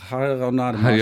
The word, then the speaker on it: Hanerau-Hademarschen: Das ist eine Leihgabe aus Radenau-Hademarschen. Radenau-Rademarschen, so heißt Hanerau, das. Hanerau-Hademarschen. – Hanerau-Hademarschen.